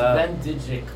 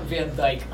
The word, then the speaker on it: Van Dijk: It should be like, it's Van Dijk. It's not Van Dijk.